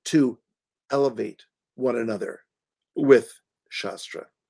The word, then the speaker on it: Shastra: the the um this is when we turn Shastra into Shastra. Mm. Okay? Yes, yes. Scripture into weapons. And it's the difference between a long A and a short A.